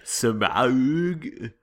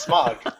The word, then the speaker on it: Smaug: Smaug. – Some crazy ass Kiwi coming up with some weird pronunciation. I love New Zealand, but come on, guys. It's smog.